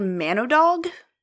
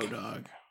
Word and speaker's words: Mannowdog: So it looks like Manodog. And it's almost like it's man or dog. – Manodog.